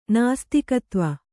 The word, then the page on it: ನಾಸ್ತಿಕತ್ವ: kannada